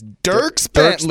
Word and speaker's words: Dierks Bentley: Dierks Bentley. – Dierks Bentley,